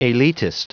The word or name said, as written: elitist